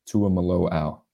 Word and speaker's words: Tuimoloau: Andrew, correct me if I'm wrong here, but the pronunciation, JT Tuimoloau. – I think you nailed it right there.